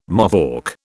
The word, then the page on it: Mohawk: British English